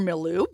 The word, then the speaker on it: milieu: It was always my particular milieu."